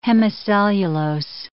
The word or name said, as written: hemicellulose